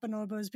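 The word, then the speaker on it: bonobo: And I heard you in a recent interview on Species Unite, another podcast, where you were speaking about chimpanzees and bonobos. Bonobos, mm-hmm. Sorry, right. – I completely mispronounced that.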